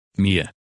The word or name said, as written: Mia